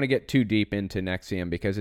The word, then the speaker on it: NXIVM: In 1998, Keith Rainier and Nancy Salzman founded Nexium, NXIVM is how it's spelled, a personal development company offering executive success programs, ESPs, and a range of techniques aimed at self-improvement.